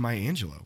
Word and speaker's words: Maya Angelou: Also, one other note, I mispronounced Maya Angelou's name as Maya Angelou. So you're probably like, who's Maya Angelou? Well, it's actually Maya Angelou.